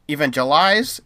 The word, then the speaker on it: evangelize: And it was his kids, and they would in evangelicalize. Is that how you say it? Evangelize. There we go.